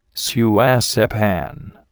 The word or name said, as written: suacepan